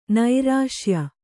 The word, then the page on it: ನೈರಸ್ಯ: nairasya